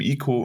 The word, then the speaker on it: Ico: Alles perfekt gesagt, wie du es gesagt hast, sogar bis hin zu äh, richtig ausgesprochen Team Ico und nicht.